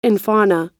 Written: infauna

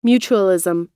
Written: mutualism

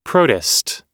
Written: protists

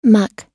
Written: muck

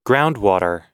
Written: groundwater

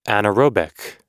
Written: anerobic

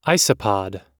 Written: isopods

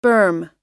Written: berm

